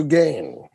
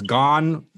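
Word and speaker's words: Gane: And he's actually said that you can pronounce his last name either one of those ways. Gon, Gain. I like to say Gain because it sounds fucking badass.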